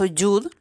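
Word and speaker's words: Yud (יוד): Y curiosamente el yod o el, eh, en este caso Yud, lo pronuncian así en hebreo, es eh, el número 10 y se pronuncia Yud y de alguna manera representa la letra J, ¿de acuerdo?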